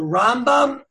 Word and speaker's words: Rambam: So the way you say it is, you have the Rambam, you put the accent on the first syllable, then you have the Ramban. You put the accent on the second syllable.